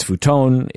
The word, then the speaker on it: futon: Well, even my name, that's not how you pronounce it. – So it's futon. It's udon. – Because it's very Latin sounding vowels, which is very odd.